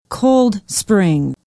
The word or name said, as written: Cold Spring